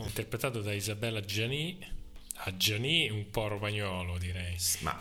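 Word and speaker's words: Adjani: Sì, Ajani, non si dice la D, credo. Sì, Ajani.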